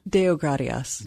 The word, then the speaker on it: Deogratias: Deogratias.